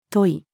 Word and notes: 問い: female

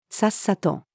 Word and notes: さっさと: female

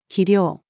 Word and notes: 肥料: female

